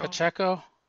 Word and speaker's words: Pacheco: Pacheco.